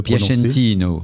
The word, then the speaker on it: Piacentino: Piacentino. Ah, c'est très beau. En français, c'est Piacentino. Hein.